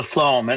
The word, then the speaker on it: phloem: Today we want to introduce you to an extremely important system in a plant, the phloem, and it's pronounced flow as in F-L-O-W-E-M.